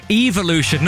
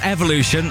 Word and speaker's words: evolution: It just says, annoys me that is mispronouncing evolution. It's evolution, not evolution.